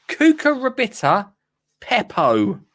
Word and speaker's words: cucurbita pepo: But this week's is cucurbita pepo. Cucurbita pepo. Last time, you want me to spell it? C-U-C-U-R-B-I-T-A pepo.